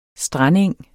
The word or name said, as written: strandeng